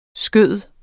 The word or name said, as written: skyder